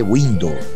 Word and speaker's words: Weendo: Se escreve W-E-E-N-D-O, mas se lê Window, a pronúncia deles lá.